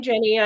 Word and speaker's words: Jenny: People do that to me all the time. I think it's because people who have the name Jeannie spell it with an IE at the end. But no, I'm really Jenny.